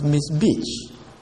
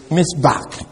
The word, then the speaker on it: mizbeach: Miss Beach, M-I-Z-B-E-A-C-H, Miss Beach. Although somebody was saying, "Well, why didn't you pronounce it Miss Beach?" Well, except that that's the Hebrew doesn't. This is what they call. It has a K sound. This is the Hebrew word there, so it should be. That's so it's not pronounced like chi. So that is why we pronounce it Miss Back.